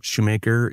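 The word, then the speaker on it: Schumacher: And my last name, Shoemaker, is not spelled like it sounds. It's S-C-H-U-M-A-C-H-E-R.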